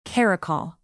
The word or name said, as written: caracal